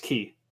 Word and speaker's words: Chi: Key, by the way. – I said this like I remembered. – The X is pronounced Key.